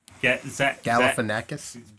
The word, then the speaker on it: Galifianakis: I'm going with Zach Galifianakis. That would be really funny. – I don't think that's how you say his name, but Galifianakis. – Galifianakis.